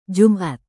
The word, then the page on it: jumat: Indonesian